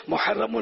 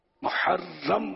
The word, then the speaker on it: محرم: محرم کون بولے گا, رام مشدد. خیر اگر ہا حلق سے نہ بھی نکالی تو کم از کم محرم ہی بولے, لیکن نہیں بولتے صحیح تلفظ.